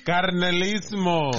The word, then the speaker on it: Carnalismo: Condalismo, right? – No Condalismo. – Oh, Carnalismo. – Carnalismo. – Pronounce the R.